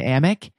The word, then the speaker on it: Amick: Is that Amick? How you pronounce it? Medchen Amick. – Yeah.